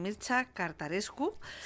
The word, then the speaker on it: Mircea Cărtărescu: Bueno, ¿quieres adentrar en el mundo de, a ver si lo digo bien, Mircea Cărtărescu? – Sí.